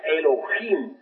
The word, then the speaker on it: Elohim: Ahora, Elohim es una palabra que se escribe con H, Elohim, que se pronuncia Elohim, porque la H suena como J, y significa diosas y dioses.